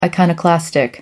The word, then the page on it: iconoclastic: American English